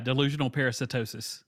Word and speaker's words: delusional parasitosis: Yeah, delusional parasitosis.